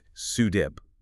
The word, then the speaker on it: SUDEP: SUDEP, S-U-D-E-P, sudden unexpected death in epilepsy. – And we really need to pause here on the pronunciation and the gravity of this. It's pronounced SUDEP, like one word.